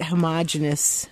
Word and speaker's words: homogenous: Is it homogenous or homogeneous? I heard two guests recently say the latter, and I'm just wondering how to say it. – I'm pretty sure that homogenous is the right way.